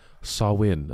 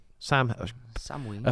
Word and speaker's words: Samhain: Halloween holiday has its roots in the ancient Celtic festival of Samhain, a Gaelic word pronounced Samhain. Oh, Samhain? – Samhain.